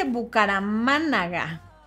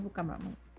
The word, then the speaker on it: Bucaramanga: Aquí dice Bucaramánaga. Pero lo dije bien, según yo, si es Bucaramanga. No, sí, sí es Bucaramanga.